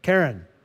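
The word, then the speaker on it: Karen (קרן): Karen.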